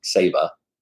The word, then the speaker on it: Saba: It's pronounced Saba. – Saba Rock. – Saba. – Yes, it is.